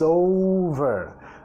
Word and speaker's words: over: When it's over. Acá, over, over. Acentuando la primera sílaba, pero mira la vinculación.